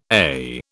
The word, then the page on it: AIH: British English